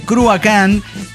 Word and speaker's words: Cruachan: Bueno, sí, entre otras cosas hay que decir, por si andan googleando o algo así, que Cruacán es gaélico antiguo. Por eso se pronuncia, es escocés. El Cruacán, cuando en realidad se escribe Cruachan. Ustedes encontrarán que la rola se llama Cruachan, pero en realidad se pronuncia de esa manera por una cuestión que el escocés, el galés, el gaélico antiguo, claro, el inglés británico, tiene sus, sus, el irlandés tiene sus...